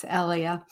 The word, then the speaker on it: Ellia: It's Ellia.